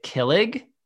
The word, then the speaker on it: kilig: It's K-I-L-I-G. Mm-hmm. I would pronounce it kilig. – Close, yeah. It's kilig. – Kilig, so emphasis on the second part.